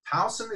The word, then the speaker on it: Towson: She currently leads advancement marketing and communication strategy for Towson. Is that how you pronounce it? – Yes.